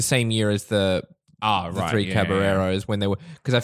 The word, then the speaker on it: Caballeros: Although it's Spanish, so those L's are not pronounced, Zane. You racist. – Okay. – The Three Caballeros. – Caballeros.